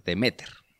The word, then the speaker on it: Deméter: No, tiene el acento en otro lugar. Deméter.